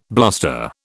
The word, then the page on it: bluster: British English